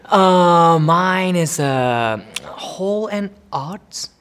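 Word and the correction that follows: Hall and Oates: Hall and Oats.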